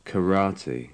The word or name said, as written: karate